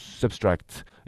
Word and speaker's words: SBTRKT: Substract, ça s'écrit S-B-T-R-K-T, mais ça se prononce Substract.